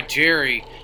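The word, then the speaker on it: Gerry: Gerald, yeah, but I go by Jerry. But I spell it with a G. It's usually spelled with a J. So I get a ton of people calling me Gary, which makes no sense to me.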